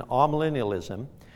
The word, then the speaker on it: amillennialism: The A, often pronounced aw, before millennium in amillennialism, the A means not.